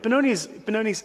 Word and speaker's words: Benoni: But Benoni's absolutely amazing. I've got to tell you, don't know if you know the history of Benoni, but the word Benoni is actually pronounced incorrectly. It's actually pronounced Ben-Oni.